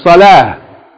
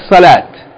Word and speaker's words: salah: Those words are correctly pronounced zakah, not zakat, and salah, not salat.